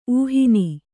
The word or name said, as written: ಊಹಿನಿ